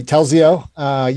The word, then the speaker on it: Telzio: Telzio, your tech startup dealing with phone communication.